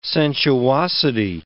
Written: sensuosity

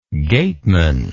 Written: gateman

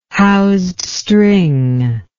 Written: housed string

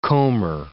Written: comber

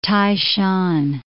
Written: Tai Shan

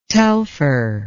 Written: telfer

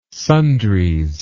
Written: sundries

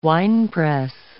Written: winepress

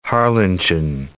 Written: Harlingen

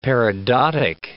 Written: peridotic

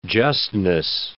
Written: justness

